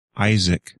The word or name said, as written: Isaac